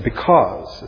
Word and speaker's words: because: He said, because. It's a two-syllable word. The first E is long. The second E is silent because the AU forms a diphthong.